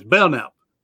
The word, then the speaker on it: Belknap: One time I mispronounced his name because he has a K in there. He says, nope, that's silent. So it's Belknap. John Belknap.